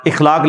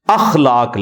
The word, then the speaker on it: اخلاق: اخلاق لفظ نہیں ہے اخلاق لفظ ہے تو اگر ہم یہ لفظ یوز کریں گے تو الف کے اوپر زبر ڈالیں